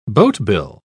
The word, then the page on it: boatbill: British English